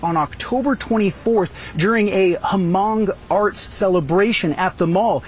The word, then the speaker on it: Hmong: And when he says Hmong, I think it's just pronounced Hmong.